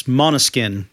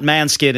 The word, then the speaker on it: Måneskin: It's Måneskin, and I'm doing my best to pronounce the Danish word there. Not Manskin, it's Måneskin. Måneskin.